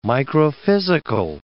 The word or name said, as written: microphysical